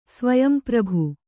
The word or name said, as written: ಸ್ವಯಂಪ್ರಭು